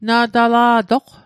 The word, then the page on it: na'tala'toq: Mi'gmaq